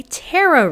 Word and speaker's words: tarot: So is that spelled T-E-R-R-O, or how do you spell that? – So it's spelled T-A-R-O-T. – Oh, right, tarot, like a parrot, but yes.